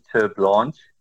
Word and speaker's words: Terblanche: He's a very interesting character, and that name, we pronounce it Terblanche.